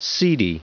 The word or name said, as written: seedy